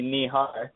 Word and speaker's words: Nihar: You got it sort of right, Janet. It's actually Nihar, but very Nihar.